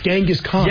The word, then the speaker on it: Genghis: So the thing is, I'm right. It's Genghis Khan.